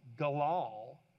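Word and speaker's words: galal (גלל): It's pronounced galal, and it just means to roll.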